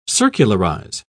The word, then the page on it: circularize: British English